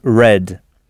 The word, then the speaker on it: read: C'est « read ». Alors, ça s'écrit comme le verbe « nu », c'est-à-dire « r-e-a-d », mais ça se prononce « read ».